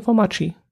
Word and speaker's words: Formaggi: Ich hatte noch eine relativ günstige Pizza für 9,90 Euro bestellt, die Pizza Quattro Formaggi oder Formacchi, so spricht man es glaube aus. Nee, Formaggi. Formaggi müsste es heißen.